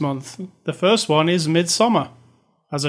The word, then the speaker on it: Midsommar: I'm going to say it right from now on. Midsommar. – Because that's AR at the end instead of an ER.